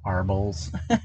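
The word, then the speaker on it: Arboles: I'm pretty sure I'm going to mispronounce this, but it's in Arbolis, Colorado. – Arbolis, Arbols.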